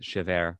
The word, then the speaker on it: Chabert: But she is of Cajun and Italian ancestry, so maybe as a French Cajun, she pronounces it Chabert.